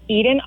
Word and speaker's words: Edan: So I'm on Edan Archer on pretty much everything, but my name is spelled E-D-A-N and then Archer.